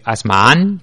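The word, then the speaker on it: Asmahan: Sí, que busque por eso, pues bueno, se escribe Ashmahan sí. Correcto, lo has pronunciado correctamente, bueno.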